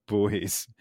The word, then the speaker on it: buoy: Someone's like, it's not pronounced buoy, it's pronounced boy. I'm like, actually, I looked it up because I had to look it up because I didn't know this. – I've never heard of buoy. Boy is how you say it in the UK, in England. – Apologies. – Buoy, because we're Americans, that's how we say it. We're not being dumb, we're just being American, which is maybe the same thing.